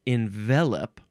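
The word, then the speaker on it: envelop: It is envelope. There's no E at the end. You pronounce it envelope.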